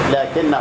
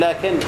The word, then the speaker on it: لكن: معروف مثلا كلمة لكن، لكن هو الله ربي في اللغة العربية الألف تنطق ولا تكتب، اللام في الكاف على طول لكن.